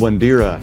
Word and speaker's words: Wandera: Well, Richmond Wandera, am I pronouncing that right? – That's correct.